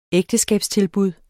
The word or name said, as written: ægteskabstilbud